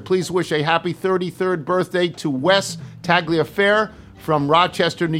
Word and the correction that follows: Tagliaferri: York, and the last name is pronounced Taglafair, so I got that wrong.